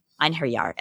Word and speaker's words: Einherjar: So as we said, Kraft is surrounded by his homies, the, how do you pronounce it, Enyar? Enyar? – Einherjar? – Einherjar.